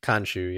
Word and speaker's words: Khonshu: It's, I don't know how to pronounce it, but it's like Khonshu. – Khonshu, yep.